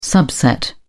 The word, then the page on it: subset: British English